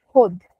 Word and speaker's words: Hod: The correct pronunciation of our name is Hud.